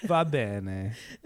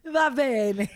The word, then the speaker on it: va bene: Va bene. – Va bene.